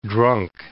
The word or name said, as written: drunk